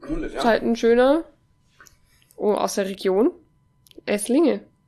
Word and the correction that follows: Esslingen: An alle draußen, das habe ich jetzt wunderschön ausgesprochen. Esslingen am Neckar.